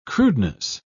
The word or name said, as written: crudeness